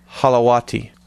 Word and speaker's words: Holowati: Oh, he tells me how to pronounce it down here. Let me do it right. Holowati.